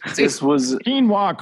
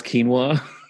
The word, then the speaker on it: quinoa: He still can't pronounce quinoa. If you ask him, like if you say, Dad, what does this say? He says quino.